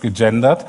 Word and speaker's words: gegendert: Wie spricht man das aus? Ja, heißt gegendert.